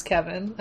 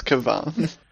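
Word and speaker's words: Kevan: If you pronounce his name wrong, we will ban you. It's Kevon.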